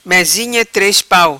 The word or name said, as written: Mezinha tres pau